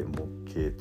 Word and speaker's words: 木鶏: 木の鶏と書いて木形と読みますまさにね、